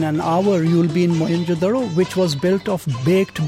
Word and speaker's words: Mohenjo-daro: Mohenjo-daro. I pronounce it the local way, Mohenjo-daro.